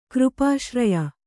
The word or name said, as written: ಕೃಪಾಶ್ರಯ